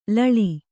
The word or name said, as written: ಲಾಳಿ